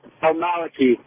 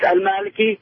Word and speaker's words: El-Maliki: The way you pronounce his last name is El-Maliki. Like, if you say it like straight up, like the way I would say it to my father, it's El-Maliki, but like, you know, El-Maliki.